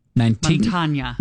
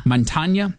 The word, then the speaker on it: Mantegna: Joe Mantegna. Does that ring a bell? – Montagna. – Montagna. Mm-hmm. I pronounced it phonetically.